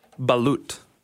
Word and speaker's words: Balut: Balut. It's spelled B-A-L-U-T.